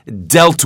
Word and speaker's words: dealt: When 'deal' becomes 'dealt' with the final 't, d e a l t. Make sure you change your pronunciation. 'Deal, dealt.' – 'Deal' 다루다, 처리하다는 뜻이지만 그것이 t가 붙으면 과거 다루었다 혹은 dealt 다루어졌다 이렇게 과거나 과거분사 형태가 똑같은데 yeah. – 예, 발음은 에 발음 바뀌는 거예요.